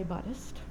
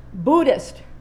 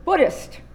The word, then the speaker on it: Buddhist: And there are some people who do say Buddhist. And also could be heard as Buddhist. So the second it is, Buddhist, rhymes with wood, so I'm still not doing it right, Buddhist.